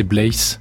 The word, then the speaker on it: Bleys: Oui, il y a une prononciation méridionale et puis celle du reste de la France. Mais enfin, euh, celle que j'ai toujours euh, apprise depuis mon enfance, c'est Blaise.